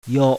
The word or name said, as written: YO